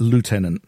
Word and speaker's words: Lieutenant: Then he stuck a person called Lieutenant John Payne. Now, I pronounce it the English way, the Americans will pronounce it Lieutenant.